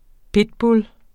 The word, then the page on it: pitbull: ˈpedbul; ˈpidbul